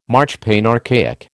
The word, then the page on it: marchpane: American English